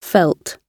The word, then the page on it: felt: British English